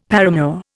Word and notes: paramour: Indian

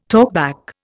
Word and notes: talkback: Indian

